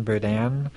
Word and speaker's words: Berdan: It's just my name, Harrison Burdan. – Spell it, the last name. – B-E-R-D-A-N.